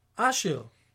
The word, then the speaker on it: Asher: The word asher in Hebrew, if you change the emphasis, becomes a boy's name and is pronounced asher.